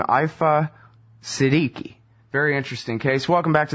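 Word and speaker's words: Aafia Siddiqui: Well, you did mangle her name a little bit. It's Aifa Siddiqui, but you're very close.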